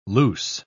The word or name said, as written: loose